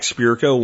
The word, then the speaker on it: Spirko: But just look for Jack Spirko, one word, S-P-I-R-K-O, for those that keep spelling my name wrong.